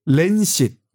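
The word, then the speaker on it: Lancet: 보도 자료를 근그 데 보도 자료를 낸 근거가 그 논문이 렌싯 LANCE, 란셋으로 쓰고 렌시스로 읽는다. 왜 란셋으로 그냥 일, 발음 교대로 읽으면 되지 왜 그걸 꼭그 사람들은 렌싯 이렇게 읽을까요? 음. 또 렌에 액센트가 렌에 들어가서 네. – 렌시시지 렌싯 이것도 아니더라고요.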